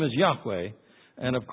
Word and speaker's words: Yahweh: But the name of the God of the Israelites, the personal name of the true God, was Yahweh. That's probably the way it was originally pronounced.